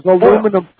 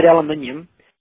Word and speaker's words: aluminium: Yeah, well, you guys can't help it if you can't pronounce the word aluminium. Like there's radium and osmium, all the other EMs. But for some reason, the Americans seem to cut out the EM when it comes to aluminium.